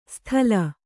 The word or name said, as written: ಸ್ಥಲ